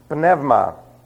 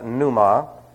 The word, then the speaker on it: pneuma: Now, if you hear it pronounced pneuma, that's only because people don't know how to speak the Greek language. Pneuma, every letter in the Greek language is pronounced. There are no silent letters, and diphthongs, when they combine, have certain sounds.